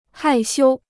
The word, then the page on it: 害羞: hài xiū